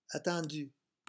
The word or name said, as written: attendu